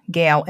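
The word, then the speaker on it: Gail: I am your host, Gale M.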